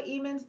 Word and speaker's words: Hemans: Oh, well, my name is Angela Hemans. The H is silent.